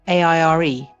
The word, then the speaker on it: AIRE: Hattie set up her own business, AIRE, pronounced AIR, last year, which uses artificial intelligence to support eclectic real estate projects.